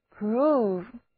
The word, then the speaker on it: prove: 虽然这个单词是一个开音节单词，但是字母 O 的读音却是长的 U prove。